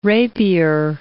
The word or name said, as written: rapier